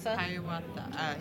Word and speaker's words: Hiawatha: Like Haratha? – Hiawatha. – I can't pronounce that one.